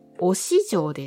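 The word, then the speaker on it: 忍城: 観光地のまず一つ目は、おし城です。忍ぶ城と書いておし城ですね。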